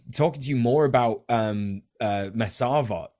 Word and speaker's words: Mesarvot: And it's Mesavot. In Hebrew, this means we refuse.